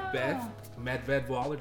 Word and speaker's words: Beth Medved Waller: I'm here with Beth Medved Waller.